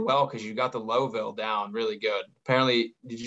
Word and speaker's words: Louisville: You got to say it like you're, there's a saying, say Louisville like you're drinking bourbon. You know, Just slurred a little bit, Louisville.